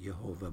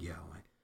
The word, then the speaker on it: Yahweh: Some like to pronounce it Jehovah, but it's more accurate, Yahweh.